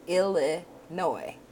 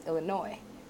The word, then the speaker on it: Illinois: That's Illinois. Aha! Yeah, I don't know how it got its name. Probably from, I don't know. Okay, so you don't pronounce the S. This one's Illinois.